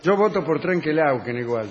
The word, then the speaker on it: Trenquelauquén: Tengo aquí un saludo para Angélica del Trenquelauquén, que es como se pronuncia, sí, que nos escucha.